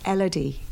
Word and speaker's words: Elodie: Elodie.